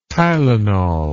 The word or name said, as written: tylenol